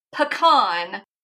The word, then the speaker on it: pecan: From a experience, I am a third generation pecan farmer. So that is the correct pronunciation. No questions asked.